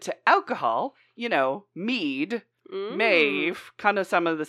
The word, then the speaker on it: Medb: She is Queen Maeve, and it's spelled a variety of different ways. So M-A-E-V-E or M-E-D-B is another way that it's spelled, but still pronounced Maeve.